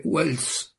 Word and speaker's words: Waltz: Kenneth Waltz, come lei dice, pronuncia, io lo faccio alla tedesca perché è di origine tedesca il nome, secondo me, quindi, l'autore Waltz